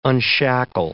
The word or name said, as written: unshackle